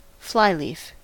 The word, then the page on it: flyleaf: American English